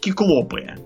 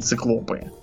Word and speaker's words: киклопы: Вот, например, правильно говорить киклопы, а не циклопы.